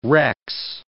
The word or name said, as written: rex